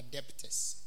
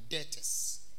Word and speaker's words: debtors: Don't go and say we are debtors. We are debtors. The B is silent.